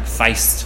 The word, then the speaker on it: feest: and it was F-E-E-S-T, and they actually pronounce it like feist.